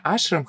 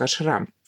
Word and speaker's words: ашрам: Я знаю, что здесь многие ребята говорят именно ашрам, хотя я тоже наводила сведения. Правильно говорить все-таки слово это с ударением на второй слог ашрам.